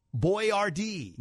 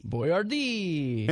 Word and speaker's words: Boiardi: And then here's how you spell his last name. You'll get this here when I spell it. B-O-I-A-R-D-I. Boyardee. – Boyardee. – And so they anglicized it, they said. They made it, you know, Boyardee. – Boyardee, so we could read it.